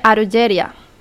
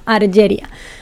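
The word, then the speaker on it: Argeria (アルジェリア): ¿Cómo se dice Algeria en japonés, sabes? Eh, se dice Argeria. Ajá, Argeria. Pues eh, al este con Argeria, Algeria en español.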